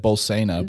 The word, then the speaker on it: Bolsena: Um, pronounce that again. How did you pronounce that? Uh, I said Bolsena. – That sounded great.